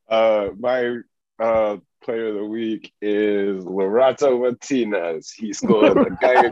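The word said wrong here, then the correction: Lautaro: Lotaro, whatever.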